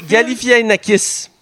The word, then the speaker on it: Galifianakis: Zach, euh, Galifianakis.